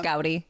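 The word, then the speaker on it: Gaudí: Or I guess it is pronounced Gaudi. – Yeah. Well, it's Gaudi. – It is Gaudi. Yeah. That's what I thought.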